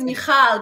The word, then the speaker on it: Michal: Thank you for welcoming me to your show and for saying my name so brilliantly well, because it's not an easy name to pronounce. No, perfect. Like, no, my name is Michal, but I say in the book that, you know, I'm Israeli. It's a Jewish Israeli name.